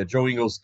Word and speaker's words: Joe Ingles: Yeah, Joe Ingles, that is the correct pronunciation.